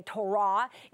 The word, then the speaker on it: Torah: It's Torah, Torah. It's the way we would say Torah is the way someone in Judaism might say it with the accent on the second syllable.